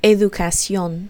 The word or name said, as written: educacion